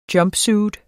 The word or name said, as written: jumpsuit